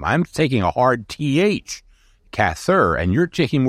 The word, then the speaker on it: Cather: emphasized that it be it, it was Cather, and it would be pronounced in a similar way to rather.